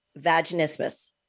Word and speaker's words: vaginismus: One of the things we were talking about at the break that we're going to get right into is vaginismus. Did I just say it right? – You said it perfectly.